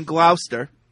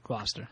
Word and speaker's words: Gloucester: He says, I used to live in Gloucester. Did I say that right? – No. – What is it, Gloucester? – Gloucester, yeah. – Near where Paul lives. – That is correct.